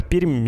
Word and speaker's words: Пермь: Правильно же не Пермь и не Пермь со смягчением «м». А местные говорят парадоксально. У них «м» твердое, последнее «ар» мягкий. Ну, то есть «пермь».